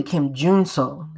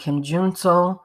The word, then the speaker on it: Kim Jun-so: Shin Ye-chan, Kim Jun-so, it's really Kim Jun-so, let's get that correct, Kim Jun-so, Jeon Do-yeom, I like his name, that's a swell name, Do-yeom, yeah, that's a good name.